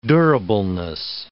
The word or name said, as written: durableness